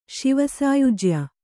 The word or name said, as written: ಶಿವಸಾಯುಜ್ಯ